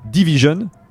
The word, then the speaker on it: DVSN: mais qui s'appelle Division, que vous avez, qui s'écrit DVSN exactement.